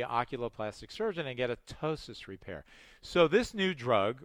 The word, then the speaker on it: ptosis: If it falls lower than that, then you may have ptosis, and that's the word, starts with a P, the P is silent.